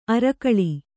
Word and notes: ಅರ್ಗಳ: Kannada

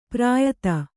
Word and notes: ಪ್ರಾಯತ: Kannada; prāyata

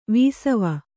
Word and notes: ವಾಸವ: vāsava

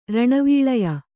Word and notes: ರಣವೀಳಯ: kannada

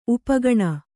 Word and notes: ಉಪಘ್ನ: upaghna

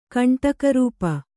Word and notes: ಕಂಟಕರೂಪ: Kannada

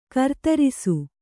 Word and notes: ಗೃಧ್ರಸಿ: gřdhrasi

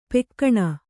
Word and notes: ಪೆಕ್ಕಣ: Kannada; pekkaṇa

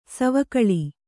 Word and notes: ಸವಿಗೋಲ: savigōla

